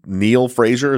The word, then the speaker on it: Neill-Fraser: And what a great transition into our case that we're talking about today, Bob Chappell and Sue Neill Fraser. Is that how you pronounce Correct. – It right? – Correct, yes.